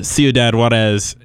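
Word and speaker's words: Ciudad Juárez: And the Ciudad Juarez haircut? – Yeah.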